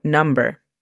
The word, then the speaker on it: number: La pronunciación fonética de esta sería igual que hace un momento que mencioné licencia, L-A-I-S-E-N-S, e license, y número, number, N-A-M-B-R.